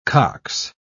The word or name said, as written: cox